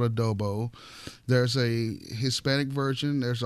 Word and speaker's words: adobo: It's adobo. – I was going to say adobe.